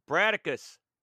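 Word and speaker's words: Brataccas: But since Bandersnatch never actually released, we will be taking a look at what did release, which is Bracatus? Is that how you pronounce that? – Bradicus. – All right, fair enough. – Bradicus. No, it's like Spartacus, but with Brat.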